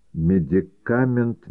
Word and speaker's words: медикамент: А однажды Олеша предложил Маяковскому купить у него рифму «Медикамент медиками». Маяковский, не задумываясь, дал ему рубль. Олеша удивился, почему так дешево. «Потому что, говорится, медикамент с ударением на последнем слоге», — пояснил Маяковский.